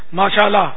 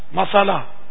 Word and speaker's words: ماشاء اللہ: اب وہ آج کل کے تلفظ کے ساتھ کہے گا تو شاید فائدہ نہیں ہوگا, جیسے ماشاءاللہ مسالہ, اس سے تو کوئی فائدہ نہیں ہوگا. ماشاء اللہ ماشاء اللہ ماشاء اللہ.